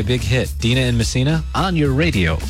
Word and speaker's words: Messina: This is the TJ Show and Dina in Messina, New York. – How's it going? – Is that how you pronounce it? Messina? – Yeah.